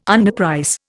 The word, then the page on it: underprice: Indian